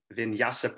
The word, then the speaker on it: Vinyasa: She's now a yoga instructor with Spirit Bear Yoga and My Vinyasa Practice.